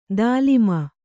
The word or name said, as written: ತಾಲೀಮ